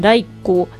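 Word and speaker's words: 雷粉: ということでね、はい、ライコをちょっと調べてみたんですけれども、うん、こちらの雷の粉と書いてライコで調べると、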